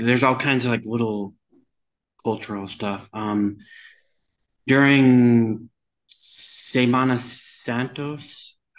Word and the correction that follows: Semana Santa: Semana Santa, that's Easter week.